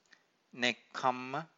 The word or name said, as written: nekkhamma